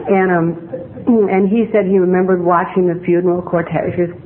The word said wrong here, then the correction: cortege: what do you say, going to... Hi there, Tom. Mr. Pronounce that word. The cortege. Thank you.